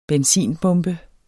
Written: benzinbombe

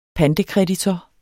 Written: pantekreditor

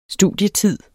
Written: studietid

